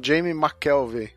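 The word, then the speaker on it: Jamie McKelvie: Dessa vez eu vou falar aqui da dupla inglesa Kieron Gillen e Jamie McAvill. Falei certo, gente? Aí, acho que sim, né? O último não, Jamie McKelvey.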